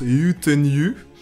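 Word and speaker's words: Yötön yö: C'est la chanson qui s'écrit Yotonyo, mais ça se prononce Yutenyu.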